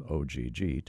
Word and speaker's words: OGG: But there's another pilot with close ties to Hawaii whose last name actually is OGG, Og, and many Kama'aina old enough to remember what he accomplished mistakenly assumed that Kahului's three-letter code was designated in his honor.